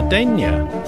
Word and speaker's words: Denyer: Helping me review this week's news is Green Councillor for Clifton East, Carla Denyer.